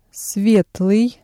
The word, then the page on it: светлый: Russian